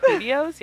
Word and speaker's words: Hoodios: Because it's spelled, y'all. It's spelled, Julio is spelled H-O-O-D-I-O-S.